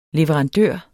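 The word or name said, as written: leverandør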